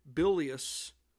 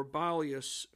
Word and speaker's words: bilious: Well, it just so happens, folks, that right after September 1st, many of Oliver Perry's sailors came down with bilious or bilious fever, that's spelled B-I-L-I-O-U-S, bilious or bilious fever, which resulted from having such symptoms as nausea to vomiting.